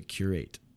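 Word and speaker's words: Cúrate: It's called Karate. – Like it's supposed to curate. – Okay. – With an accent over the E. – Oh, I see. – Curate. – It was like the proper pronunciation of karate. – Curate. – Curate. – Yeah, curate in Spanish.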